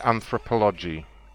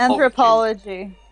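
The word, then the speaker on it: Anthropologie: Am I saying it right? Is it Anthropology? – No, I think it's Anthropology.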